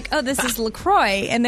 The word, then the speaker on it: La Croix: And they even say on their website, it's definitely pronounced La Croix. And if you ever get confused about it, just remember it rhymes with enjoy.